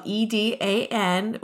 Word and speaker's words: Edan: But my name is spelled E-D-A-N, which is an anagram of my grandmother's name, which was Edna.